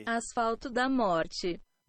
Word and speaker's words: Asfalto da Morte: Sorry, the name of the album it came off of is Asfalto da Morte. – Asfalto da Morte. – Morte. It's Portuguese. See, I'm probably pronouncing it the way you would pronounce it. Spanish, Portuguese, a little bit different.